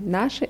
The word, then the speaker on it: наши: Gut, und die Betonung bei наши ist auf A.